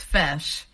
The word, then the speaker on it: feis: The Columbus Fesh, which is spelled F-E-I-S, is the Irish dance competition.